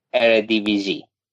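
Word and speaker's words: Eredivisie: چی واسه اسم لیگ اون دفعه به من تلفظ درستش گفتی بذار یادم بیاد اردویزه اردویزی اردویزی اوکی آره خواهم خب، که او نگفتم چون اون دفعه گفتم اردویزه فکر کنم آره, آره،, آره.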